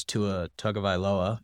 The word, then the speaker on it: Tua Tagovailoa: And now it's Tim Boyle versus Tua Tagovailoa.